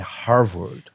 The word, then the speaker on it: Harvard: Como pronuncia? É Harvard.